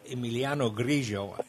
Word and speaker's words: Emiliano Grillo: But the guy in second place, Emiliano Grillo, and I like saying that because I can pronounce those L's correctly.